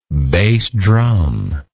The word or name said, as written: bass drum